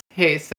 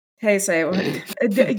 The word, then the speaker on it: Heisei: I'm really sorry. However, it is pronounced Heisei. – Heisei.